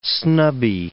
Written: snubby

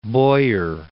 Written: Bojer

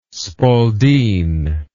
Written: spaldeen

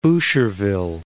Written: Boucherville

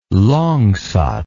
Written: langsat

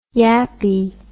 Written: yappie

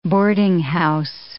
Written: boardinghouse

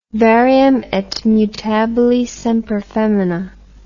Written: varium et mutabile semper femina

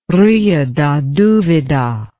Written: Rio da Duvida

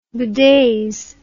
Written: bedaze

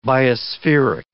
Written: biospheric

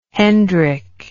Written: hendrick

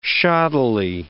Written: shoddily

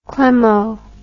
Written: Clemo

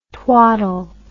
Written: twattle

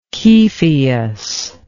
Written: Kepheus

